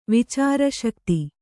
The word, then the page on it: ವಿಚಾರಶಕ್ತಿ: vicāra śakti